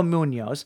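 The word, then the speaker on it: Muñoz: Armando Muniz, M-U-N with a little thingy over it, O-Z. Munoz, Minoz, Minoise. I don't know. Is that, how do you say the N with the squiggly over it? – it's like an N-Y sound. – Yeah. – Munoz.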